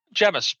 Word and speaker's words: Jemas: So now Bill Jemis, or am I saying it right? Jemis? J-E-M-A-S? – Jemis. Bill Jemis.